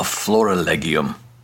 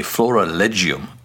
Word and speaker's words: florilegium: And that is the practice of keeping a florilegium, or a florilegium, if you're using the ecclesiastical pronunciation of Latin.